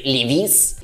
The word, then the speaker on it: Levi's: Джинсы Levi's именно так произносят. Никаких этих ваших Levi's-ов там и не признают.